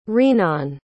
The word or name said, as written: rinan